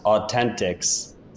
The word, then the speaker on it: AU10TIX: It's AU10TIX. I think it's pronounced Authentix.